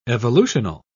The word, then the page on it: evolutional: British English